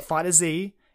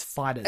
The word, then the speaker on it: FighterZ: And it is officially pronounced Fighters. – Fighters, yeah. – Not FighterZ, it's Fighters. – It makes, I guess, both makes sense, I guess, but... – No. Bandai Namco said.